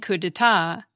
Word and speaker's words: coup d'etat: The same goes for the silent P and silent T in coup d'etat, and the silent D and silent X in Grand Prix.